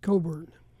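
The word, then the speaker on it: Cockburn: It's kind of a, I'm told, it's a Scottish name, and that's the way it is pronounced in the old country, so to speak. It is pronounced Coburn.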